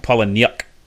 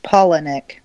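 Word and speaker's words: Palahniuk: Yeah, it's like Paula Nyuk. Got an I in there. – It's pronounced Polonic. – Okay, Polonic.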